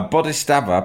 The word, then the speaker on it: Bodhisattva: A Bodhisattva, pronounced Bodhisattva, is a compassionate person.